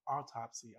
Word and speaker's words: autopsy: You said Auto Spy. – I did. Y'all. – No, I probably would have said something like that. – Oh my God. Y'all, autopsy. I know how to say it.